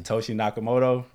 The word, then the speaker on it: Satoshi Nakamoto: His name is Satoshi Nakamoto. And his whole premise behind Bitcoin was... – Hold on, pause. What's up? The brakes. You did very well. You said that name like perfectly. – Satoshi Nakamoto.